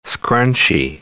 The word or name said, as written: scrunchy